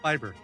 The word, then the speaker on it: Bieber: Is it Bieber or Bieber? How are we doing that one? Bieber? – It's pronounced Bieber.